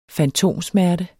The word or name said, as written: fantomsmerte